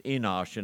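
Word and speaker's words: Enosh (אנוש): Now, let me point out again, the word man here, The first one is a broad word. It's pronounced Enosh, and it means mankind.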